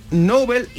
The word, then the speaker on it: Nobel: Ah, Porque en inglés, Nobel y noble se pronuncian casi igual.